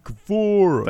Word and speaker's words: Guevara: Right. Say it right. – Sammy Guevara. – Thank you.